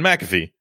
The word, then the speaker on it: McAfee: John McAfee.